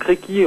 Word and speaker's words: Reiki: Donc, Reiki en japonais, c'est deux syllabes. Re, qui indique le caractère cosmique universel de cette énergie, et ki, force vitale essentielle.